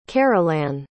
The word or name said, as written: carolann